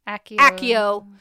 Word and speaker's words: Accio: Accio is how it is pronounced in the audiobooks.